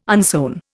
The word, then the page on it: unsewn: Indian